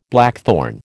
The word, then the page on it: blackthorn: American English